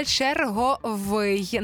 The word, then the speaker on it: черговий: Правильний один варіант, і це черговий. Наголос на «й».